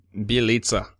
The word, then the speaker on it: Bjelica: Well, I'm pretty happy that Bjelica sounds a lot better than what I said last time. – Yeah, I believe I saw some of the emails that came through, and I believe that's the correct pronunciation.